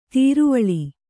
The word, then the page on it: ದ್ರಾವಿಳ: drāviḷa